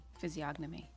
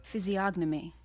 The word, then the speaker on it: physiognomy: Physiognomy. You said it right the first time, right? – Yeah, I had it right the first time, didn't I? Physiognomy.